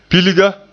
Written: pilga

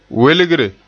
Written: welgre

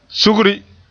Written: sugri

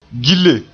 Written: gille

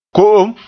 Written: ko'om

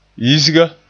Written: yisga

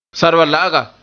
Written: sarbala'aga

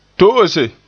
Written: to'ose

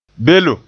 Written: belem

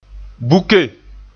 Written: buke

